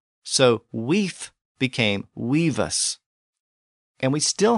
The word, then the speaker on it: wif: In Old English, the word was spelled W-I-F or win-I-F, and it was pronounced weef with an F sound at the end.